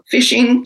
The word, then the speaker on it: phishing: Phishing, it's spelled P-H-I-S-H-I-N-G, but it's just like when you go to fish, F-I-S-H.